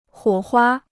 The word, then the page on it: 火花: huǒ huā